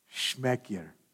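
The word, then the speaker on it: șmecher: Can you say that with me? Schmeckier. You have to roll your R when you say it. Schmeckier.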